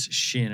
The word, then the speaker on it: Chêne: So and it is Shin.